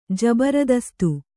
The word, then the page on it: ಜಬರದಸ್ತ್: Kannada